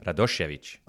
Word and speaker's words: Radosevic: Why don't you say my name properly in Croatian then? – Radosovic. Wrong. Is that better? How do you say it? – Radosevic. – Oh, Radosevic.